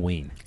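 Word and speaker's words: Len Wein: Wayne, is it Wayne or Ween? Len Ween. Len Ween.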